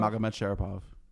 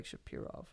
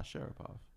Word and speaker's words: Magomed Sharapov: Wait, how do you say it? Margot Shapiro? – Magomed Sharapov. – I thought it was like Shapirov or something. – Nah, Sharapov.